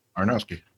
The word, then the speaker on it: Aronofsky: Darren Aronofsky.